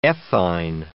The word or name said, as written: ethyne